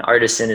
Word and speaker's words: Artisan: And my Instagram is my name, Matt Artisan. And Artisan is spelled just like Artisan, A-R-T-I-S-A-N.